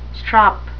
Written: strop

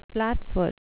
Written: flatfoot

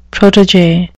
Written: protege

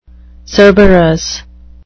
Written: cerberus

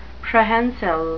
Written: prehensile

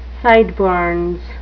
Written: sideburns

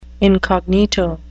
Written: incognito

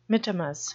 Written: mittimus

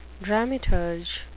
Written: dramaturg

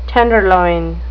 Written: tenderloin

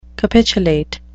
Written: capitulate

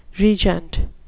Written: regent

